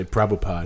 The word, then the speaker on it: Prabhupada: They don't say the last A. They just say Prabhupada.